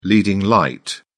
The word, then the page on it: leading light: British English